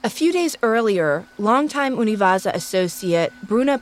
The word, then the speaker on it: UNIVAJA: which I believe is pronounced Univaza, noticed something odd.